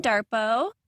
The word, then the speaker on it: Darpoh: Today on the WOMED, I'm being joined by Nurse Kim Darpoh.